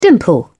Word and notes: dimple: British English

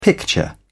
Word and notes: picture: British English

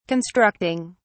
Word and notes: constructing: British English

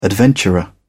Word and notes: adventurer: British English

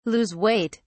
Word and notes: lose weight: British English

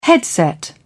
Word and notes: headset: British English

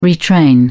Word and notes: retrain: British English

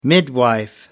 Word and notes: midwife: American English

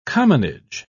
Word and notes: commonage: British English

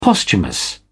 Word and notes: posthumous: British English

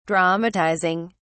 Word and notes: dramatizing: British English

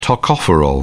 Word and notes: tocopherol: British English

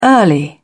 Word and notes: early: British English

